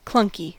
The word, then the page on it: clunky: American English